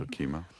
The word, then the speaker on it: Okemah: This is from May of 1911 in Okemah, Oklahoma. I'm not sure if I'm pronouncing that correctly. – That's right, Okemah.